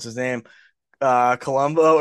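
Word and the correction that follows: Coulombe: Coulomb, yeah.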